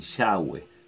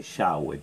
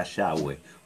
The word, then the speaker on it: Yahweh: En el hebreo, recuerden que alguna vez lo hablamos, no hay vocales, entonces usan solo las consonantes y eh, cuando vos pronuncias las consonantes corridas en hebreo te sale Yahweh, te queda Yahweh o llave o llave según como, lo quieras pronunciar.